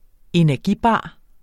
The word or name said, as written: energibar